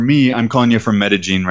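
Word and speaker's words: Medellín: Anytime you have a double L in this region, in this accent of the people that live here, they pronounce it as a J sound rather than a L, like a Y sound, like okay. In classic Spanish. So they call it Medellin.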